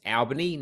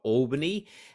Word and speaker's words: Albany: My actual hometown is Albany, which is about 400 k's south of Perth, pronounced Albany, not Albany. So that's an important distinction.